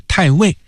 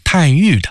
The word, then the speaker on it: 太尉: 剧中高俅的官职是太尉，过去李少春先生排演《野猪林》的时候呢，包括后来拍电影，是念作太尉的。而在古代官职呢，现在一般念作太尉，所以这版《逼上梁山》啊，也就按照一般的念法，把高俅的官职念作是太尉。